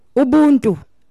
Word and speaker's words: Ubuntu: Ubuntu. – Ubuntu.